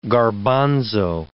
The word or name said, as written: garbanzo